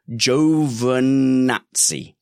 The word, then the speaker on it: Giovinazzi: Ian Caldwell says it's pronounced Giovinazzi.